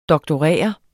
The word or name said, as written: doktorere